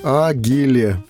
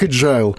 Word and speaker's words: agile: латинскими буквами пишется как «агиле». Как ИГИЛ, Агиле. А, агиле, а, а да. Ну, вот это произносится как agile.